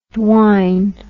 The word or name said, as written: dwine